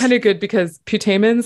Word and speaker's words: putamen: no, actually they pronounce it putamen. I had to look this up when I did the audio. Yeah, it, it's putamen.